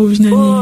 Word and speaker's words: Ovinani: C'est quoi ça, Ovinani? – Ovi, comme il prononce, O-V-I-N-A-N-I. – Oui, la chanson, c'est quoi?